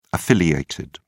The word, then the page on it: affiliated: British English